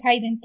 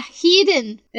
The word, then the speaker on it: Hidden: No, es Hidden. Sí, pero se pronuncia Hidden. Es un verbo irregular. De veras. ¿eh? ¿Nunca pusiste atención a tus clases de inglés?